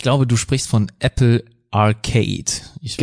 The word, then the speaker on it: Arcade: aber manche würden aber wahrscheinlich auch Arcade sagen, aber ich würde es jetzt einfach mal Arcade, wie die alten Arcade-Hallen aussprechen.